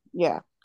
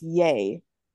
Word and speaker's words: Ye: Formerly known as Kanye West, pronounced Ye, has long been. Okay, so it's Ye.